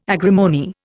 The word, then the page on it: agrimony: Indian